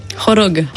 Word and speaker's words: Khorog: Comment prononce-t-on Khorog ? – Khorog. – Khorog, pardon. – Khorog, ouais, Khorog. En prononciation locale.